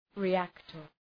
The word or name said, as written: reactor